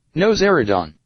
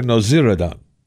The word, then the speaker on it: Nowzaradan: How, how do you say his name, seriously? Nowzaradan. Dr. Nowzaradan. Nowzaradan. Nowzaradan.